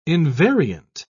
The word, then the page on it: invariant: British English